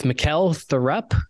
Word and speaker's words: Mikkel Thorup: Okay, so we are live with Mikkel Thorup.